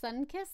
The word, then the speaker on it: Suncus: They are in the order Eulipotypha, and they're in the family Soricidae, and their scientific name is Suncus Etruscus. – Suncus? Like Suncus the drink? Or just you could pronounce it like that. It's spelled S-U-N-C-U-S.